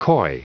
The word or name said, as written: coy